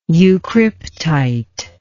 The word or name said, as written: eucryptite